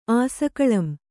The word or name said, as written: ಆಸಕಳಂ